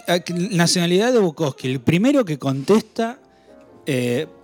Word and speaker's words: Bukowski: Eh, a Galiano y a Bubovsky Y discos dice... – Bukowski.